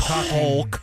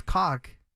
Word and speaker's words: caulk: Remove the caulk remover. – I legit thought it was cock. – It has an L in it. – Oh. – Yeah. – Who knew? – That's how you say it. – C-A-U-L-K, right?